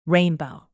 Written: rainbow

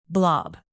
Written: blob

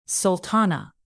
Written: sultana

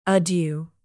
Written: adieu

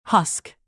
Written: husk

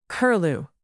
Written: curlew

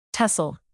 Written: tussle

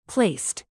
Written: placed

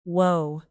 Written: woe

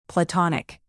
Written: platonic

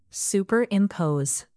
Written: superimpose

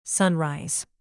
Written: sunrise

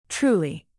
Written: truly

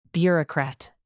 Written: bureaucrat

